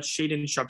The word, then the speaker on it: Shaedon Sharpe: Talk to me about Shaedon Sharpe.